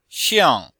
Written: xiang4

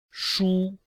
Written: shu1

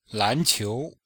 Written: lan2qiu2

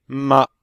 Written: ma5

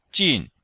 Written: jin4

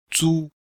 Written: zu1

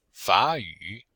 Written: fa3yu3

